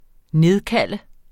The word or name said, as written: nedkalde